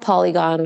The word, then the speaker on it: Poligon: So it's called Poligon, which is spelled P O L I G O N, like the shape.